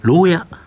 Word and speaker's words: LOWYA: 福岡県福岡市にですね、本社がある家具やインテリアなどをアルファベットで LOWYA と書いてですね、牢屋という各、ブランドでですね、ネット通販している企業になります。